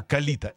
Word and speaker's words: калита: Значит, вы говорите про попугая-монаха, который иначе называется, правильное ударение ставить, калита. Не калита, да. А калита. Это попугай калита.